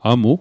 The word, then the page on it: amu: Mi'gmaq